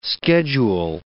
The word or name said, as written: schedule